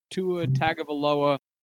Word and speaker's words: Tua Tagovailoa: I have Tua Tagovailoa from Miami. – Excellent pronunciation. Good job.